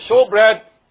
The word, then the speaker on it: shewbread: And if you don't know what a showbread is, now, you know, King James is written as S-H-E-W-B-R-E-A-D, which is showbread. A showbread. Like, you pronounce it as showbread. Like show.